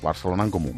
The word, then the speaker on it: Barcelona en Comú: Barcelona en Comú, ¿lo he dicho bien? – Muy bien. – Barcelona en Comú.